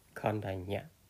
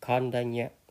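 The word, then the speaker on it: Koṇḍañña: And his teacher was name is Kandan Kandanya there may be different pronunciation okay but it's very similar to what my pronunciation is kandanya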